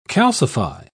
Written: calcify

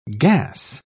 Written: gas

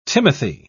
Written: Timothy

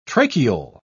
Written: tracheole

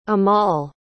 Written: amall

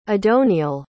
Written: adoniel

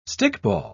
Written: stickball

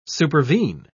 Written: supervene

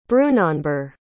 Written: brunanburh

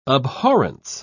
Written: abhorrence

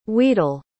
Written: wedel

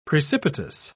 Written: precipitous

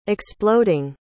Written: exploding

